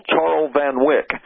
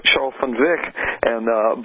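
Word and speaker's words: Charl van Wyk: In the way it's written, he always gets called Charles Van Wyk. And he explained to me in a joke one day that one of his children heard somebody call him that, and they looked at him and said, "Daddy, who is Charles Van Wyk?" we don't know who that is, but it's actually pronounced, as you have said, Charles Van Wyk.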